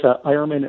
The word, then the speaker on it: Eyermann: Oh, it's Ironman. It rhymes with fireman.